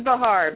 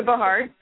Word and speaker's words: Bahar: It's Lisa Bahar, but don't worry. People butcher it all the time. I just go with it, but it's Lisa Bahar.